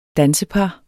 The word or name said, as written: dansepar